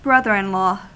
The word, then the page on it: lanko: [ˈlɑŋko]; [ˈlɑŋkʷo̞]